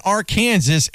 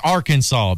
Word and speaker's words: Arkansas: And as Darren McFadden said after they had won that game, he says, just remember, it ain't Arkansas, it's Arkansas, baby. That's how you pronounce it.